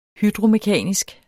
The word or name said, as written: hydromekanisk